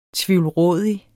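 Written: TVIVLRÅDIG

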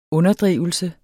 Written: UNDERDRIVELSER

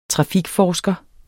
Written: trafikforsker

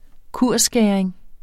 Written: kursskæring

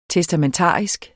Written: testamentarisk

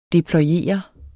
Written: deployere

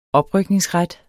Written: oprykningsret

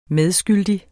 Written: medskyldig

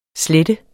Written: slette

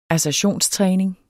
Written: assertionstræning

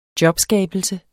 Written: jobskabelse

